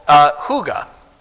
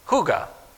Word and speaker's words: hygge: So their happiness, they attribute to a thing that they call hygge. Hygge. Now, if you were going to spell that, it's H-Y-G-G-E.